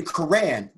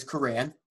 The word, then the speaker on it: Kharran: It's actually Quran, just like the Bible. My name is Quran, but it's just spelled Swahili, but it's Quran, just like the Muslim Bible.